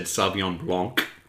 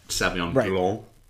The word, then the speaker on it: Sauvignon Blanc: like when he said Sauvignon Blanc instead of that C, you don't actually pronounce it so much as it escapes into your nose, Sauvignon Blanc. You gotta swallow that at the last second.